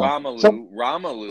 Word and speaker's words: Romelu: And, I mean, there's, again, the name, I can't even pronounce it, Romella? Lukaku. I don't know. – Romelu. – Thank you.